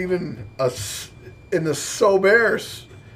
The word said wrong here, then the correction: Sorbet: Sorbet. – Sorbet. – Yeah, the tea is silent.